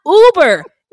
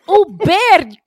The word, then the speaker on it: Uber: And all of these damn white people were pronouncing it like the car service. It's not Uber. It's Uber, cocksuckers.